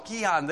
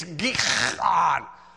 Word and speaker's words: Gihon: I said, I know I'm saying it wrong. Gihon. They said, Gihon, never heard of that, Gihon. And so then he went to one of the guides and said, Gihon. He said, it's Gihon. I said, that's probably it, yes.